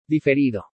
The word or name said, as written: DIFERIDO